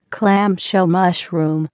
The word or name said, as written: clamshell mushroom